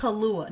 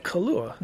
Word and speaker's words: Kaluuya: I hate Kaluuya. No, no, no. Say his name right. – Kaluuya.